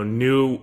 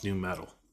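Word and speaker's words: nu metal: But for the people at home, when she said, you know, new NU, I was just like, yeah, that's how you say it. But unfortunately, not everyone knows that. So I have to be mindful of the people who don't know. That's how you pronounce new metal.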